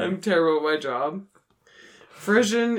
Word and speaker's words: frisson: No, it's frish. Yeah, it's pronounced frisson, but it's F R I S S O N.